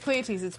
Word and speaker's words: Pleiades: Am I saying that right? – Pleiades. – Pleiades. Yeah, Pleiades. I was like, wait, I don't think yeah. we're saying it right. – No, yeah. – we're not.